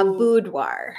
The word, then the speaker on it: boudoir: A boudoir. – I'm doing a boudoir shoot. – Your accent kind of comes out a little bit. – My southern Tennessee. – The Tennessee. – I'm doing a boudoir shoot on the 20th.